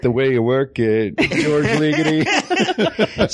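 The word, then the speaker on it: György Ligeti: I think I've always been pronouncing it Gheorghe Ligeti, which I think is totally wrong. I think it's George, like George, but with George? – George Ligeti.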